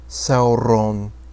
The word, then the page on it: Sauron: Quenya